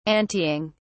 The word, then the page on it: anteing: British English